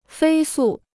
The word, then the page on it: 飞速: fēi sù